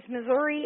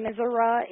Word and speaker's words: Missouri: But, no, the point, I believe what it is Missouri is more of a urban pronunciation and Missouri is more of a rural pronunciation.